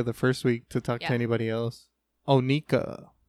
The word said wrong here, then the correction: Onyeka: Onyeka. – It's Onyeka? – Yeah. – Okay. – I think. – I don't know how to pronounce it. – The Y comes before the E.